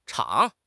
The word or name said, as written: chang2